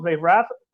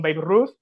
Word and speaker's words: Babe Ruth: Babe Rath, o españolizando eh, la pronunciación Babe Ruth, y vienen esos Yankees, que es el inicio de la gran dinastía que es hasta nuestros tiempos, ¿no?